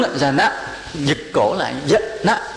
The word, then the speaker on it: Jhana: Chứ trong cái tiếng Bali mà cái nào mà có dấu hiền á, thì đọc là A, mà không có dấu thì đọc thành ngắn, thành dấu sắc. Ví dụ đây đọc là Jhana.